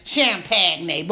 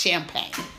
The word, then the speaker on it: champagne: But a lot of time in Ebonics, we like to tease and say champagne, but we know it's pronounced champagne.